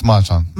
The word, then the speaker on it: Mahjong: Yeah, it's Mahjong.